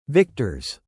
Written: victors

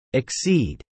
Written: exceed

